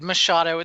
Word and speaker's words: Machado: Machado. It's close enough. It's common. – Machado. I just Machado. Too much emphasis on the wrong syllable there.